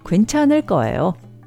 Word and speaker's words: 괜찮을 거예요: 괜찮을 거예요, pronounced 괜찮을 거예요, 괜찮을 거예요.